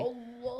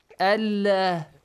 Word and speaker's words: Allah: Whenever Fatha or Dhamma comes before the word Allah, you have to pronounce it Allah heavily. Allah. But if Kasra comes before it, Allah.